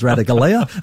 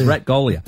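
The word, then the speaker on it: Ratugolea: So for those that previously knew him as Radagalia, his name is Ratgolia.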